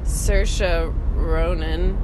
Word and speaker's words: Saoirse Ronan: Almost as cool a name as Saoirse Ronan.